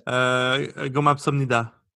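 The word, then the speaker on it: 고맙습니다: It's a gomapsomnida.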